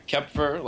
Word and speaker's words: Kopfer: It depends if you want to say it in German or English, but yeah, Kopfer, like a silent O, okay. Is how most people pronounce it.